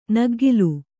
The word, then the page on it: ನಗ್ಗಲು: kannada